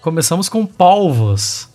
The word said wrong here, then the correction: polvos: Retiro o oba, porque essa, essa pronúncia aí não, não rola. Ah. – É. Come, começando com polvos aqui, então.